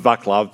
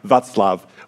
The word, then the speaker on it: Václav: People sometimes call him Vaclav because they see the C there as a C, but it, in Czech, the C without an accent on it is pronounced S, so it's like Václav, which is the Czech version of Wenceslas, actually.